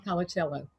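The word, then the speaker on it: Colacello: So in the beginning, I wanted to read to them just a few sentences written by Bob, and I didn't even know how to pronounce his name, Colicello. – Bob Colicello. – I said it right.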